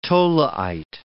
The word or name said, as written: tholeiite